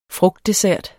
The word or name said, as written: frugtdessert